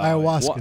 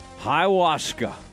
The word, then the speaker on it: ayahuasca: How you say it? What's that What's some of that uh, peyote type stuff that he smokes? Uh, ayahuasca. – Wa- ayahuasca. Ayahuasca.